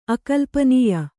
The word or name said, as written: ಅಕಲ್ಪನೀಯ